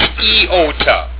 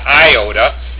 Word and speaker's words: iota: It's EOTA. It's not IOTA. It's EOTA. So when people say, oh, I didn't have one iota of concern for that. No, well, that's the lazy American way. It's EOTA, not IOTA.